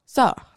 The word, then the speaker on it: sort: spell, sor. So it's spelled S-O-R-T, but you pronounce it S-O-R. And okay. You add a little bit of roll on the R to sound fancy.